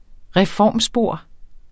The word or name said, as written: reformspor